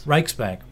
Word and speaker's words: Riksbank: Riksbank.